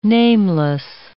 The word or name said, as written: nameless